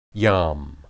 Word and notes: yam: Hebrew

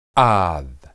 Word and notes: ad: Hebrew

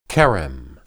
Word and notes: kerem: Hebrew

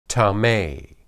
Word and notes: tame: hebrew

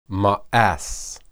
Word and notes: maas: Hebrew

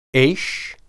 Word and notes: esh: Hebrew

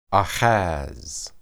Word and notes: ahaz: hebrew

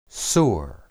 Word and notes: sur: Hebrew

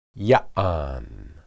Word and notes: yaan: hebrew

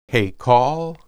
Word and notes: heykal: Hebrew

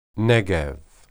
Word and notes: negeb: Hebrew

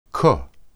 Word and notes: ke: Hebrew

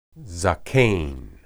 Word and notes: zaken: Hebrew

